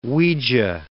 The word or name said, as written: Ouija